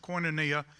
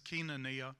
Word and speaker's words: koinonia: But anyway, one of the ways we're going to change is to build on the foundation of koinonia, which is the way you pronounce that in the South. Its real pronunciation is koinonia.